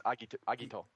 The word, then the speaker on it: Agito: Is it Agito, or is it... – It's Agito. – Agito. Yeah, I thought it was a hard G there. – Fuck. – Final Fantasy has no... Final Fantasy. Japanese has no soft Gs.